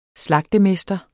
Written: slagtemester

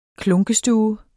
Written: klunkestue

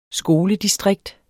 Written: skoledistrikt

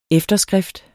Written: efterskrift

